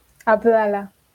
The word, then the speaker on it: Abdala: Abdala, así como suena.